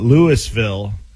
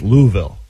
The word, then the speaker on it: Louisville: because if you call it Louisville, it's not Louisville. It's Louisville.